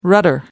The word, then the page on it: rudder: American English